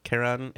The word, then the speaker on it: Chiron: I believe it's Chiron. Karen. Yeah. – Yeah. There's a couple variations on the pronunciation, but Karen is correct.